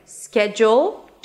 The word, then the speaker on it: schedule: So let's begin with the fact that there are actually two ways to pronounce this word. Schedule, two syllables, and schedule, three syllables.